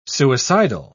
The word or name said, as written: suicidal